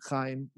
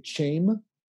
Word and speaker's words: Chaim: Chaim. Because C-H-A-I-M is not the, you know, that's how you would pronounce it if you didn't know that it was a ch sound.